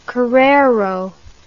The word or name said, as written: korero